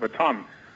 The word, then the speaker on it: Bataan: But while he was on Bataan, he came up with that little ditty, the Battling Bastards of Bataan, No Mama, No Papa, No Uncle Sam. That's in the days when people pronounced Bataan as Bataan. And so it rhymed with Uncle Sam.